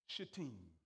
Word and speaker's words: Shittim: pronounce this word, a place called Shittim. Not the other way you pronounce it, because you have to be careful.